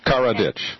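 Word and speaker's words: Karadžić: So like car in a ditch. Karadzic.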